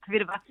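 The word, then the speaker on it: Petra Kvitová: Petra Kvitova. – I don't want to get it wrong. That's it, Kvitova.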